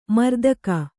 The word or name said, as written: ಮರೆದೆಗೆ